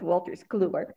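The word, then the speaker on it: Wolters Kluwer: Did I say it right? I know every time I struggle with Walters Kluwer. – It's a tough one, but you got it. You nailed it.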